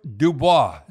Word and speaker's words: Dubois: So anyhow, the trade we should talk about is Pierre-Luc Dubois.